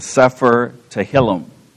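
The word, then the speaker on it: Sefer Tehillim (ספר תהלים): It came to be known as the Sefer Tehillim. I think that's, is that how you'd pronounce it, George, do you think? Close enough.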